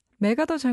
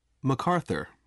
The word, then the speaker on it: MacArthur: 그래서 원래 발음도 본토 발음으로 한번 해주시죠. – MacArthur. – 여기에 가깝지만 여기 한국에서는 옛날부터 맥아더 맥아더 이렇게 불렀으니까 저도 편의상 그렇게 발음하도록 하겠습니다.